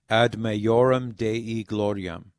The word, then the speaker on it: Ad Maiorem Dei Gloriam: So the correct pronunciation in Latin would be Ad Maiorem Dei Gloriam, for the greater glory of God.